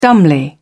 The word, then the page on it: dumbly: British English